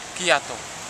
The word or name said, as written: giato